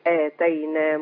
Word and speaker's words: آتينا: ولقد اتينا بقى جئنا، لكن هي اعطينا. – اه اتينا. – اه ناخد بالنا الالف ده لابد من نطق الالف الماديه اللي بعد الهمزه. – حاضر. ولقد آتينا موسى الكتاب وقفينا من بعده بالرسل، وآتينا عيسى ابن مريم.